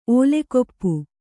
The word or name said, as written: ಓಲೆಕೊಪ್ಪು